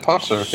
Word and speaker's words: Pontzer: You got it, Pontzer.